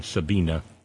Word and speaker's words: Sabine: Sabina. Sorry about that, Steve in New York. One pronounces the German Sabina. There are no silent letters in German.